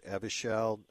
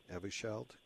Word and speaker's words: Evusheld: The other day, they pulled Evusheld. Is that how you pronounce it? Evusheld? – Yeah, that's right.